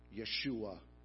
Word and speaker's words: Yeshua: Do you know how the Jews pronounce the name Joshua? Yeshua.